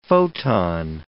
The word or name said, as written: photon